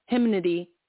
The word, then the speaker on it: hymnody: Hymnody. Is that a word? – Tell Google to pronounce it. – I'm about to. Oh, I pronounced it right. – Hymnody.